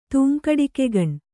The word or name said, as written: ತೂಂಕಡಿಕೆಗಣ್